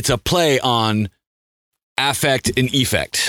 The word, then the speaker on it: EAffect: So I just said, I pronounce it as effect with the E and the A is silent.